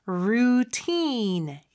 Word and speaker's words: routine: 자, 발음은 그냥 루틴이 아니죠. routine 이렇게 발음을 하고요.